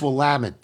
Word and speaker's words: Willamette: You've proven yourself quite adept at using the mnemonic of it's Willamette, damn it.